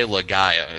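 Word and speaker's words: Laga'aia: Oh my god, I was preparing myself to pronounce his last name because there's about 30 vowels and it's Jay LaGaia is how you pronounce it.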